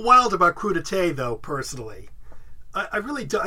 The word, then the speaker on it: crudité: It's pronounced crudité.